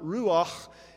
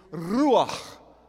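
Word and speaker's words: ruach (רוּחַ): The first, you have to be able to roll your R's. And the second is you have to have that on the end of it. So it's not ruach, it's ruach.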